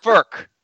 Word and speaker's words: Frk: My company employs someone by the last name F-R-K. It's pronounced Firk.